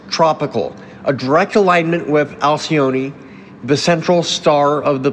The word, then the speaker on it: Alcyone: How did you pronounce that? – I usually say it as Alcyone, kind of rolling it out like that. But hey, pronunciation can be a little flexible, so whatever feels comfortable works.